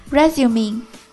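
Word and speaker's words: résumé: Resume R-E-S-U-M-E，这是一个在招聘中常用的词，表示简历。注意这个单词有两个发音，当它做名词表示简历时读作 resume，做动词表示重新开始读作 resume。 Mirage M-I-R-A-G-E 海市蜃楼。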